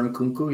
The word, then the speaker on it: Nkunku: Christopher Nkunku. – Mm-hmm. – Probably just butchered that, but Christopher Nkunku, yeah.